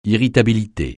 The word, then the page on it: irritabilité: francais